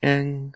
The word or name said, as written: eng